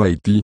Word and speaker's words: Haití: No supe por qué tal nombre le pusiesen, siendo toda una lengua de los de Cuba y de la Española, pues no se llamaba sino Haití, la última sílaba aguda.